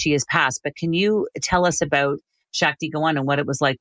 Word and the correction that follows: Shakti Gawain: Yeah, it's actually Shakti Gawain is how you pronounce it, Shakti Gawain.